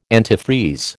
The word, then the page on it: antifreeze: American English